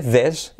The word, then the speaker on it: this: Pero que sepas también que uno es con S, this, y otro es con S sonora, z, these. Y luego la otra parte de la pronunciación es que estas cuatro palabras aparecen con el sonido semiclave TH. This, que es como el sonido Z español, ¿vale? Zapato, Zaragoza, pero activando las cuerdas, th, ¿vale?